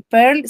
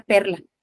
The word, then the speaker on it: Perl: Hay uno que se llama Perl y Perl se escribe como si fuera perla, pero sin la A. entonces es, Perl